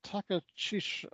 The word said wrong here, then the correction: Takachiho: Is it Takachiko? – I believe that's what it's. Takachiho. Akahisa Takachiho. I believe it's how it's pronounced.